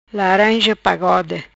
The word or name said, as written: Laranja Pagode